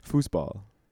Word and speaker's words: Fußball: Fußball. – Fußball.